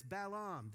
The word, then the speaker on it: Balaam: It's not Balaam, it's Balaam. This morning no one knows what Balaam is, so he's Balaam.